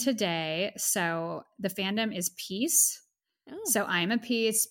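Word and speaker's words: P1ece: It's like P one E C E, and you just mm. but you mm. pronounce it as peace.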